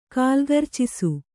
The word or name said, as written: ಕಾಲ್ಗರ್ಚಿಸು